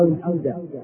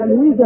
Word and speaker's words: الفيدا: فبدأوا في كتابة بعض الأفكار حول هذه المسائل، وسميت هذه الأفكار فيما بعد بالويدا أو الفيدا. الفيدا هذا هو كما سنعرف بعد قليل هو أعظم الكتب المقدسة عند هؤلاء، أعظم كتاب مقدس عند الهندوس هو الفيدا. إذا الويدا هذا أو الفيدا ينطق باللغتين، والويدا طبعا كلمة ويد كلمة سنسكريتية، طبعا اللغة السنسكريتية هي اللغة الهندية القديمة، معناها القانون أو الحكمة، معناها القانون أو الحكمة.